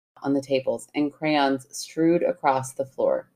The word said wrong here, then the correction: strewn: Strewn. I didn't pronounce that neat. That N very well. Do I need to say that again? – Yeah. – And crayons strewn across the floor.